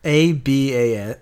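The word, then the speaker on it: à bas: A-B-A-S. A space B-A-S. – A-ba. It would just be a-ba. There's no... I do know that you don't pronounce that last consonant. So, yeah, I agree.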